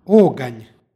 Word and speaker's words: oganj: U istom stihu pročitali smo oganj sa izgovornim a u drugom slogu.